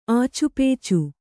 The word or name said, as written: ಆಚುಪೇಚು